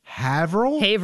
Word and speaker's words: Haverhill: Haverhill. Yeah, sorry, Haverhill.